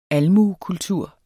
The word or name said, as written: almuekultur